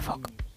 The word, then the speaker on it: folk: O sea, su regla clave es que la letra L es muda. – Anda. – Con lo cual se pronuncia folk.